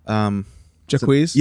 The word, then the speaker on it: Jacquees: Jacquees? – Yes.